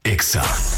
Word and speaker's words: Exa: No se dicen éxitos. Se dice Exa.